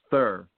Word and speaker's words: Theurer: Yeah, it's Thur. The last Thur. R is silent for some reason.